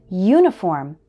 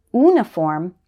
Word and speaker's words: uniform: It also happens with uniform. Don't say uniform, say uniform.